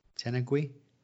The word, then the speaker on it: tenugui: Now, am I saying this right? T-E-N-U-G-U-I. Tenugui? Tenugui? – T-E-N-U-G-U-I. G-U-I. Tenugui, yeah. I guess so, yeah. Gui is not super common sound, but yeah.